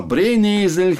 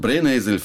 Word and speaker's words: Brennnessel: Ich musste feststellen, dass ich nach 33 Jahren in Deutschland das Wort Brennesel falsch ausspreche. Weil ich, ich habe da, ich habe immer gedacht, es geht um Esel.